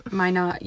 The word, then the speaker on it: Minot: Why not? But with an M. So Minot.